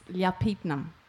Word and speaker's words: Lyapirtneme: I'm still working out how to pronounce this, but I think it's Lyapirtneme. – Yep.